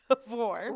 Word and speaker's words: Tavour: It is called Tavor. – Rhymes with before, actually. – Rhymes with before. It's called Tavor. It's actually spelled T-A-V-O-U-R, and